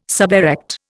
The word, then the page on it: suberect: Indian